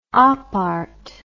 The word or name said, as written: opart